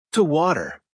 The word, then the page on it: to water: American English